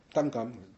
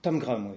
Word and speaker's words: Tangram: Alors, il y a une des nouvelles, je ne sais pas comment tu prononces ça, Tangram ? – Tangram. – Tangram, oui. Tangram, donc apparemment, c'est un jeu chinois d'assemblage et tout.